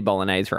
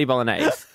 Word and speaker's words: bolognese: To spaghetti bolognese. And they pronounced it spaghetti bolognese. You don't. What do you do? – Spaghetti bolognese. – You pronounce the G.